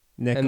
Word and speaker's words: Nikola: But Vucevic is saying that it's pronounced Nikola. – Nikola. – Like Nick Nicola. – And then Ola.